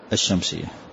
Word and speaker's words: الشمس: نعم، كذلك عدد حروفها أربعة عشر حرفا، فعيها وعلمها، يعني إذا أتت أدغم هذه اللام لا تنطقها، فتقول الشمس وتقول الساجد، يعني اللام أدغمها.